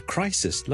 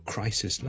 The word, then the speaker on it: crisis: 我哋头先讲咗最常见嘅例子就 crisis 啦 ,c-r-i-s-i-s, 一个危机啦 ,a big crisis 啦，但 number of crises, ok, 留意个发音啊，串字当然係唔同啦，但个发音係唔同 ,crisis 同 crises。另外仲有一件事 ,crisis 呢最尾嗰个 s 音呢係 s 嚟嘅，所以个 sis 呢係短嘅，而 crises s-e-s 呢就会变咗后面最尾嗰个 s 呢係 z 音嚟嘅，所以 crises, crises, crises。